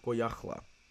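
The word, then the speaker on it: Goyahkla: Others argue that it was just a Mexican nickname, or even the mispronunciation of his actual name, Goyacla. He was, in fact, that's how his name was really said, or rather, the nickname was really founded upon.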